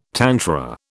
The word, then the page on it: tantra: British English